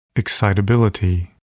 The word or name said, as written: excitability